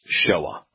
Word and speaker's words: Shoah: The most likely pronunciation based upon the letters is Shoah. But with decreasing reliability, it could be pronounced Shoei, Shua, or Shui.